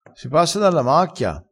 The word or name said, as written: sprunzone